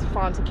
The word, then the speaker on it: Fanta: And you can also follow me on Facebook if you would like at Fonta Kante, and it's spelled F-A-N-T-A, last name K-A-N-T-E.